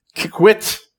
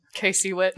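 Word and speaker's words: KCWIT: Kickwit. Is that how you say it? Kickwit? – K-C-Wit.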